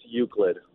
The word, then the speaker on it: Euclid: It was installed by the American Traffic Signal Company on the corner of East 105th Street and Usalet Avenue in Cleveland, Ohio. – It's pronounced Euclid. – My bad. Euclid Avenue in Cleveland, Ohio.